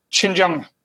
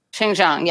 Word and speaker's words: Xinjiang: how do I pronounce that? – Xinjiang. – Xinjiang, yeah, Xinjiang.